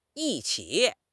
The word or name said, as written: yi1 qi3